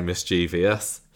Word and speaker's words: mischievous: And I hate it when people pronounce it mischievous. – Sally does tend to say mischievous. – I think lots of people say mischievous, but it's mischievous.